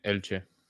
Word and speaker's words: Elche: Elche.